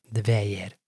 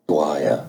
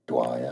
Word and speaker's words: Dwyer: It's a name of a company. Dwyer. It's how it's said in British English. Dwyer.